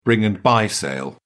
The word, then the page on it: bring and buy sale: British English